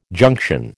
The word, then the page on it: junction: American English